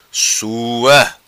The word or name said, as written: Sua